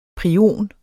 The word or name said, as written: prion